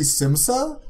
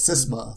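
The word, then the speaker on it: Qal Sisma: Khalsisma. – See, I wanted it to be Simsa. But no, it's Sisma. – Sisma. – Khalsisma. Also, we have no idea who Khalsisma is or was, like, or what is.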